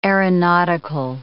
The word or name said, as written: aeronautical